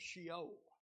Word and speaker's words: Sheol: And we ended in our lesson, I believe it was, we talked about the Hebrew concept of Sheol. That is how it's pronounced in the Old Testament.